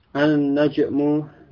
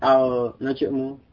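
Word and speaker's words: An-najmu (النجم): An-najmu ba'idun. An-najmu ba'idun. Bintang itu jauh. Bintang tersebut jauh. Ar-rajulu waqifun. Laki-laki itu berdiri. Ini ar-rajulu. Jadi membacanya demikian ya. Bukan al-rajulu atau al-najmu, tetapi masuk pada huruf sesudahnya.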